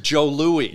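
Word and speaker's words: Jos Louis: It's J-O-S, but we pronounce it Joe Louis.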